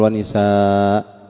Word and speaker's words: نساء: لا تقف وتقول ونساء، إنما تقف بالتنوين بعده ألف وإن لم تكن مكتوبة الألف، لأنه إنما تركت كتابتها كراهة توالي الأمثال.